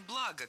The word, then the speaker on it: благо: Во-первых, хочу исправить материальные блага на материальные блага, конечно же. Ударение в слове «благо» всегда падает на первый слог. Оно неподвижное. Благо, благами, благом.